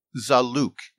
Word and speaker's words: zalouk: Can you talk about a dish called zalouk? And if I'm not pronouncing that properly, please correct me. – No, you're fine.